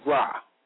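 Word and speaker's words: ra (רַע): The Hebrew word for evil is ra, pronounced R-A-H, ra,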